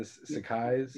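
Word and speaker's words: Sacai: The only one that I care about is the Sakai. I want a pair of those shoes so bad. Am I pronouncing that right? The Sakais? – Yep.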